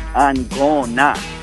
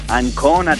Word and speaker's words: Ancona: You got to pronounce for the dolphin team, they're Ancona. You got to stress the A. Ancona Dolphins.